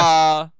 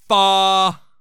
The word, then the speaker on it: fa: I want the waiter to, like, correct my pronunciation and go, it's do, re, mi, fa.